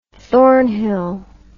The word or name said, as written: thornhill